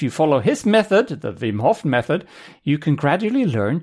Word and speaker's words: Wim Hof: And before people correct me, it is spelled with something that looks like a W, but it's actually a double V. So it's pronounced Wim Hof.